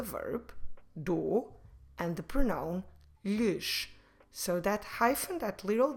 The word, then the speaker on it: lhes: Lhes, como se escreve lhes? L-H-E-S.